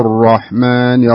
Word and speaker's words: الرحمن: إيه الراء فخمة يعني بسم الله الرحمن الرحيم. ارفع لسانك عند نطق حرف الراء.